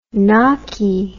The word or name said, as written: knockie